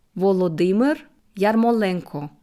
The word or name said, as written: Volodymyr Yermolenko